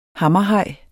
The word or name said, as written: hammerhaj